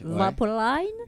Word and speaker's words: Vapoline: Vous avez réalisé un clip sur le morceau Vapoline, ou Vapoline, je ne sais pas comment vous prononcez. – Vapoline.